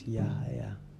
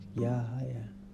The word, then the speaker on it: Yahaya: And some people will hear me say hi and they'll be like, Yo Haya? No, it's Yahaya. Where'd you get the yo from? Yahaya.